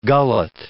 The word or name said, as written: gullet